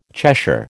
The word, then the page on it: Cheshire: American English